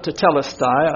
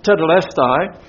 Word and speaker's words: tetelestai: Some call it tetelestai. I like to say tetelestai. And you see right under it what it's about.